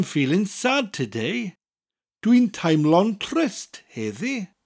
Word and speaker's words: trist: The word is trist. Trist. It's spelled T-R-I-S-T and sounds almost exactly like the English word tryst, except that R in the word rolls.